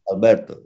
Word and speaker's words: Alberto: And the people, for example, say me Alberto, I thinking, oh, people don't say me Albert because in Japanese it's so difficult to say Albert. The people say me Alberto and don't say nothing. No, my name is Alberto.